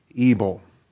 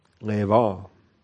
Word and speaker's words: Ebal: So Joshua built an altar to the Lord, the God of Israel, in Mount Ebal. Again, that's a terrible pronunciation. If you want to be a Hebrew pronouncing purist, do that. Leval.